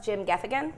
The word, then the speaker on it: Jim Gaffigan: So he loves Jim Gaffigan.